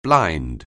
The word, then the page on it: blind: anglais